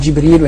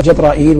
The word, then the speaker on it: جبريل: وقد ورد في نطقه 10 أقوال. نعم. يعني في الاسم. نعم. يقال جبريل ويقال جبرائيل ويقال جبرائيل وكلها واسعة. وأنا أحيانا يغلب علي أن أقول جبرائيل.